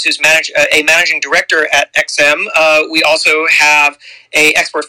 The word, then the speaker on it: EXIM: So EXIM, that's how it's pronounced, E-X-I-M, EXIM, is an independent executive branch agency with a mission of supporting American jobs by facilitating the export of U.S.